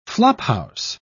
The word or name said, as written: flophouse